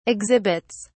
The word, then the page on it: exhibits: British English